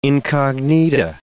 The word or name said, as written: incognita